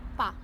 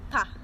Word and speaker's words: Pa: Pa. – Pa, with a B or a P? – With a P. – P. Pa.